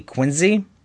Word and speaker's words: Quincy: Because at first I go, should I correct him and tell him it's actually Quincy? – Yeah, you pronounce it like a Z.